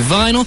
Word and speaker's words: vinyl: Records are made out of vinyl. Vinyl rhymes with Lionel, as in Lionel Blair or Lionel Richie.